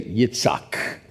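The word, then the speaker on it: Yitzhak (יצחק): Everybody say Yitzhak. Put a Y on the front of it, Yitzhak. That's close to how you would pronounce it in Hebrew.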